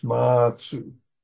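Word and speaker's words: Mazu: Basso is Japanese way of pronouncing his name. In Chinese, it's Ma Tzu.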